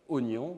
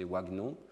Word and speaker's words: oignon: Inversement, si vous avez des mots irréguliers, comme oignon, vous voyez que normalement, suivant les règles de conversion graphème-phonème, on ne devrait pas prononcer ce mot oignon, on devrait prononcer wagnon.